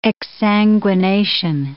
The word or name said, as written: exsanguination